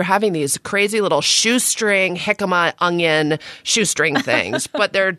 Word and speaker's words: jicama: You might pronounce it jicama. Isn't that how people mispronounce it all the time? – Yeah, well, it's spelled J-I-C-A-M-A. It's pronounced jicama or jicama. I think actually technically the correct pronunciation is jicama.